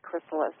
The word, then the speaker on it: chrysalis: It's chrysalis. It's pronounced chrysalis. It looks like crystal is. But it's pronounced Chrysalis Callings.